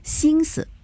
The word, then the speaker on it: 心思: 另外一个呢，就是心思啊，心思这样读的话，完全带声调了，对吧？我们这个词语呢，要读轻声，要读心思，还有一个呢，就是。